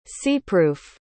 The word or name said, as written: seepproof